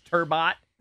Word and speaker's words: Turbot: Well, by the way, also, it was Turbo, apparently, the fish, Turbot. – Yeah. – Somebody said it's Turbo.